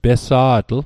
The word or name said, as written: pesa'tl